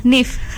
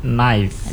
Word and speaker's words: knife: Is it correct if I pronounce nif? – Not actually. – Okay, what's the right one? – I think it is pronounced knife. – Knife. Okay. – With the lips not touching each other.